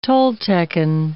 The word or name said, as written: Toltecan